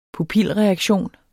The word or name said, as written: pupilreaktion